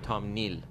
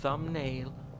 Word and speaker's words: thumbnail: تام نیل